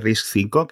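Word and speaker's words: RISC-V: Todo el tema de RISC 5, que se escribe RISC V, eh, para que lo que queráis buscar en Google, es muy complicado.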